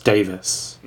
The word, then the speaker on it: Davies: Sometimes I do that because if you're English and your name is spelt Davies, it's usually pronounced Davis for some reason.